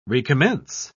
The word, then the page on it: recommence: British English